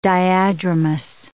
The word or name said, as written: diadromous